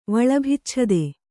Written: ವಳಭಿಚ್ಛದೆ

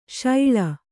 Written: ಶಾಳ

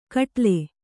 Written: ಖಟ್ಲೆ

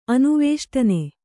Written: ಅನುವೇಷ್ಟನೆ

